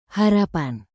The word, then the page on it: harapan: Indonesian